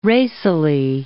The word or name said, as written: racily